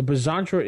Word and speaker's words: Bizantra: The Bizantra is pronounced B-I-Zantra.